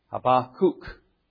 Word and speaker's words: Habakkuk: Well, please turn with me to the Old Testament scriptures, to the book of Habakkuk. If you want to be Hebraic, Habakkuk. But that sounds a little unusual.